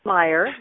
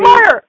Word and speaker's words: Meyer: Is it Meyer or Mayer? Because I have known you for like three or four years now. – it's, Meyer. That's okay. Everybody, Meyer.